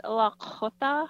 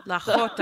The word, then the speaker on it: Lakhota: Sorry, I'm not saying it right, am I? – Oh, it's okay. It's Lakhota. – Lakhota.